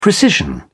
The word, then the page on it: precision: British English